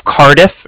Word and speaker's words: Cardiff: Calling with the Bank of Cardiff, and that's spelled C-A-R-D-I-F-F, as in Frank, Frank. So it's like car and then different.